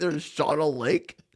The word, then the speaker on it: Shawano: S-H- A W A N O. How do you pronounce it? – Shawano? – I say Shawano. – Do you really? Do you know that my dad and stepmom live there? – Yeah. I didn't know that, but they live in Shawano because sorry, I'm a child of phonics. – No, it's pronounced Shawano.